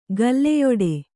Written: ಗಲ್ಲೆಯೊಡೆ

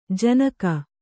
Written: ಜನಕ

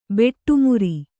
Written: ಬೊಟ್ಟುಮುರಿ

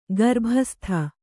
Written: ಗರ್ಭಸ್ಥ

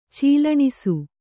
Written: ಚೀಲಣಿಸು

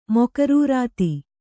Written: ಮೊಕರೂರಾತಿ

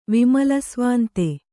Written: ವಿಮಲಸ್ವಾಂತೆ

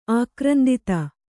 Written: ಆಕ್ರಂದಿತ

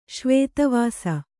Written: ಶ್ವೇತವಾಸ

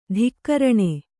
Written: ಧಿಕ್ಕರಣೆ

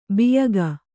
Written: ಬೋಯಿಕೆ

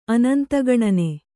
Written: ಅನಂತಗಣನೆ

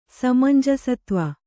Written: ಸಮಂಜಸತ್ವ

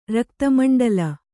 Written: ರಕ್ತಮಂಡಲ

